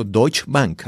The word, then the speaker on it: Deutsche Bank: Oscar, entonces en tu trayectoria, originalmente tú trabajaste con, bueno, lo pronuncio Deutsche Bank, así se pronuncia. – Sí, sí, sí, sí, sí correcto, correcto.